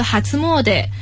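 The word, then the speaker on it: 初詣: 这个在日语里面叫做哈兹莫德，是哈兹莫德。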